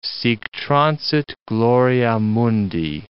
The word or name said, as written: sic transit gloria mundi